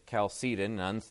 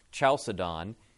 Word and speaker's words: Chalcedon: And yet, it didn't resolve everything because another group of heresies arose in the Council of, depending on how you want to pronounce it, some pronounce it Chalcedon, others pronounce it Chalcedon,